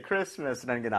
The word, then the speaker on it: Christmas: 이게 사실은 스펠링은 있지만 소리가 안 들리는 것뿐이에요. 이거를 뭐 무슨 공식처럼 외우실 필요는 없고요. 자음 세 개가 연속으로 붙어 있으면 가운데 게좀 약화가 돼요. 그래서 크리스마스 이렇게 되는 겁니다.